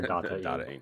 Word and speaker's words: Data-ing: With what you're doing right now, you have ShopX, you have Data-ing. Is that the correct way to say it? – Yes. Sure, man. We're still working out the pronunciations. Either dating or Data-ing, which I thought was real clever, by the way.